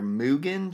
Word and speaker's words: Mugen: It's called, I'm going to pronounce it wrong, but it's Demon Slayer Mugen Train. M-U-G-E-N.